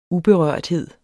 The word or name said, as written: uberørthed